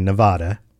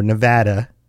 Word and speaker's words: Nevada: Well, if you've been to Las Vegas in Nevada, or Nevada, as I've been told is the proper way to say it by people who live there, in the U.S., if you've been to the downtown area of Las Vegas, it occupies roughly 3.3 square miles, so slightly larger.